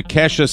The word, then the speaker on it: Kesha: Led into Kesha singing Praying, backed by...